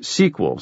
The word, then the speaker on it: SQL: an exponential leap over the famed SQL, pronounced SQL, Sachs had learned, program.